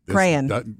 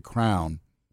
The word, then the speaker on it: crayon: Wife number three, she pronounced the word crayon, and I know this crayon. – No, I know there's crayons, but they pronounced it crown.